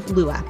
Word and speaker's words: Lugh: But anyway, Lua, L-U-G-H, Lua.